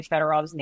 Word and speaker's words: Fedorov: This is very random, but I think about how for like our entire lives we were probably saying Sergei Fedorov's name wrong. Like, it's probably Fedorov, right? – Probably.